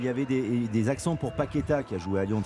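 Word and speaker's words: Paquetá: Quelle déception, Paqueta s'appelle Paqueta en brésilien.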